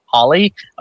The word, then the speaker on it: Halle: Halle. Is that Holly? Is that Hallie? Is that Hey Hal? I have no fucking idea. I'm horrible Holly. With English. Holly? Okay, good.